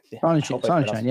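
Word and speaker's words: Sonnenshein: The CEO, I'm going to butcher his name, Michael Sonnenschein. – Sonnenschein, yeah.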